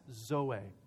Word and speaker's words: zoe: is a word zoe, and it's spelled Z-O-E, zoe.